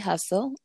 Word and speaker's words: Hussle: Okay, so my personal page, it's Candi Hussle. That's C-A-N-D, like dog, I for India. Hussle, like Nipsey Hussle.